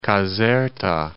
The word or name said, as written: Caserta